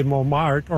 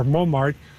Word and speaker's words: Montmartre: Well, I guess for myself, it'd be Montmartre, but it's interesting. You go different places and you say Montmartre or Montmartre, and they'll spell it M-O-N-M-A-R. You know, so in one sense, we don't pronounce it the way it's written, like all different versions of it, Montmartre or whatever.